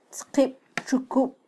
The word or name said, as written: tzqibtxukub